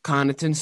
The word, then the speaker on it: Connaughton: Was this the game where Pat Covington, is that how you pronounce his name? – Connaughton started leaking blood out of his face.